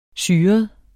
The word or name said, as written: syret